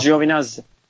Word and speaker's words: Giovinazzi: Giovinazzi mi? Giovinazzi diye mi okunuyor? Giovinazzi.